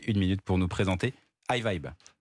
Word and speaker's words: Hyvibe: Vous êtes l'un des cofondateurs de iVibe, je le prononce bien Oui, Parfait.